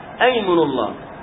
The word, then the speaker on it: أيمن الله: وأيمن الله، نقول ويمن الله في الوصل ويمن الله، لكن عندما تبتدأ بها وتريد أن تحلف تقول أيمن الله، تبتدأ بها مفتوحة، هذا على الراجح، فيها خلاف لكن على الراجح، نبتدأ بها مفتوحة نقول أيمن الله، ونكتبها همزة وصل، لأننا عند الوصل نقول ويمن الله أو ويم الله، هكذا همزة وصل، لكن كيف نبتدأ بها؟